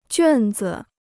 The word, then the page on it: 卷子: juàn zi